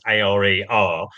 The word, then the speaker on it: Åre: Oh, and you said that you're going skiing shortly to Åre, which for British listeners is kind of like pronounced A-R-E-R.